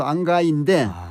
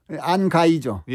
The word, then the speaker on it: 안개: 그런데 안개를 발음을 하는데 김추월 명창은 안가로 발음을 해요. – 아마 안가이로 하신 거 아닐까요, 선생님? – 그렇죠. 안가인데 이게 고어식 발음이란 말이에요.